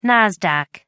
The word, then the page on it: Nasdaq: American English